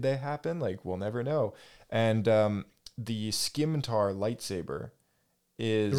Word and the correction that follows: scimitar: It's pronounced scimitar. – Scimitar? Oh, yeah, sorry. Yeah, scimitar. Yeah.